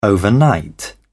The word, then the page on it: overnight: British English